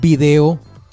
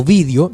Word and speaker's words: vídeo: resulta que apareció un video o vídeo. Debo decirles que las dos maneras de pronunciarlo es correcto, video o vídeo.